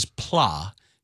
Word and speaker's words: plat: So apparently the word for flat is plat, P-L-A-T. Mm-hmm. but pronounced pla.